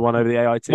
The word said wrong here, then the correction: one over the eight: One over the eight.